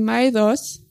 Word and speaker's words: Maedhros: Und dann sagt er, AE kann ausgesprochen werden wie I und OE kann ausgesprochen werden wie OI. Es ist also Maedhros und nicht Maedhros.